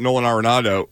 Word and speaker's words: Arenado: I don't think they're getting him, though, because the reason we know about Nolan Arenado, is that how you pronounce it? Yes. They've worked the deal out already.